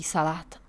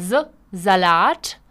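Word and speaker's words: Salat: Pozor na výslovnost, není to salát jako u nás, ale z salát.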